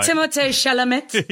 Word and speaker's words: Timothée Chalamet: Timothy Chalamet, Bob... – Let me pronounce that for you, Sam. – No, don't. I hate the guy. – Timothée Chalamet.